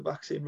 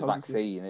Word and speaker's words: vaccine: He says vaccine. It says vaccine. And he emphasises the second syllable, whereas everyone knows it's a vaccine, and he emphasises the first syllable, and it winds me up more than it should.